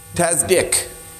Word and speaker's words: tzadik: This is tzadik. That's how it's pronounced. Tzadik. T-A-D-D-I-K. Tzadik.